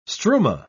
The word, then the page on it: struma: British English